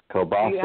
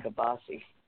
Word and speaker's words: kielbasa: Kielbasa. – How do you pronounce it? Kielbasa. No, we've always called it kielbasi.